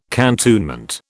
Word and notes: cantonment: British English

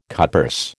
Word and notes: cutpurse: American English

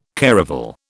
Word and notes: caravel: British English

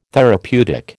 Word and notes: therapeutic: American English

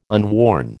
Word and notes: unworn: American English

